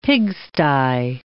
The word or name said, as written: pigsty